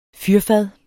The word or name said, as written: fyrfad